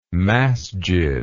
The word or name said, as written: masjid